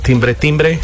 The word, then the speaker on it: Timber Timbre: una hermosísima banda canadiense que se llama Timber Timber. Timbre Timbre. Timber Timbre, se escribe.